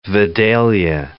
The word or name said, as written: Vidalia